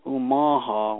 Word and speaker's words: Omaha: And then the tribe got split up in that fog, and some went upriver, and that's another interpretation of the word Omaha, which that's the way it's pronounced.